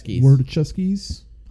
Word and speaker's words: Wachowskis: Wachowskis. – Yeah. Siblings. – Yes. They just go by the Wachowskis. – They just go by that. – Or the Wachowskis. That's what it is. – Wachowskis.